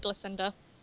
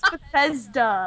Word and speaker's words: Bethesda: Okay, that's how you pronounce it. I call it Blissenda. – What? – Oh my god, it's Bethesda.